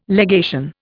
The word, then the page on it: legation: Indian